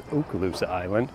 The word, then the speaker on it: Okaloosa Island: That's how that's pronounced, is Okaloosa Island.